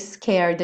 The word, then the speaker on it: scared: Aqui também, scared, a gente não pronuncia a letra E. Então, enrolou a língua pro R, scared, põe o D, scared.